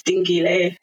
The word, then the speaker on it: Dingile: And my full name, like my actual name, is Dingile. But if you're going to pronounce it properly, it's Dingile.